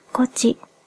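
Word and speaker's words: 東風: 普段聞き慣れない言葉ですが、実は、こちと春風は同じ風のことです。それにしても、東をこ、風をちと読むなんて面白いですね。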